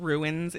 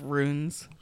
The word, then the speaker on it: Runes: Don't mispronounce it. It's like, I hate people that call it Mother of Ruins. It's Mother of Runes.